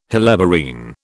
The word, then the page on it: helleborine: British English